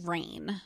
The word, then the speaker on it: Reign: And then the last thing is the show Rain. You could watch it on Netflix. It's spelled R-E-I-G-N, like rain.